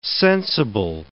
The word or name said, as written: sensible